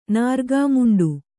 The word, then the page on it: ನಾರ್ಗಾಮುಂಡು: Kannada